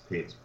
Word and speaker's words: Pitts: Name is spelled C-A-S-U-E-L. Last name is Pitts, P-I-T-T-S.